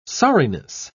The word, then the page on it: sorriness: British English